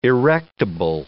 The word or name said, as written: erectable